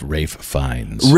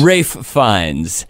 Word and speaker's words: Ralph Fiennes: But in the movie, there's the painting Boy with Apple that Ralph Fiennes, I think I pronounced. – Actually, I believe it's pronounced Rafe Fiennes. – Rafe Fiennes.